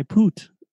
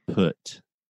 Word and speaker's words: put: No, but if you're running around saying, you know, I I poot, I poot. so the correct pronunciation is I put.